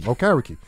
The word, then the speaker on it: Okereke: He made tackles, all over the field, you know, to the point where I learned how to pronounce his name, Okereke. And I was calling him O Carrot Cake. That's how I remember how to say his name, you know.